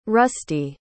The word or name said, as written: rusti